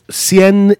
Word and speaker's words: Cien: Cien is one syllable, not two.